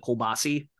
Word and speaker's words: kielbasa: And usually there's like I think it's pronounced kielbasa probably by most people, but everybody in Pittsburgh calls it kielbasi.